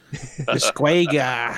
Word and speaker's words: Disgaea: It's called Disgaea. – Did it a whole lot better than I would say it. Disgaea.